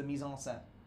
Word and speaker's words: mise en scène: They're just the mise en scène.